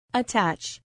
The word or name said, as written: attach